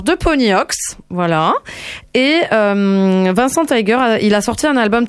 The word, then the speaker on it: Taeger: Je dirais que ça se prononce Vincent Tiger, mais écrit T-A-E-G-E-R. Tiger.